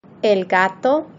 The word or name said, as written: el gato